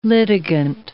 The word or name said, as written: litigant